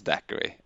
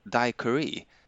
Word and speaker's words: Daiquiri: Apropå det, jag har förresten hört att det inte ska uttalas daiquiri utan att eh, det ska typ uttalas daiquiri. Det har jag också hört, men jag tycker det är svårt.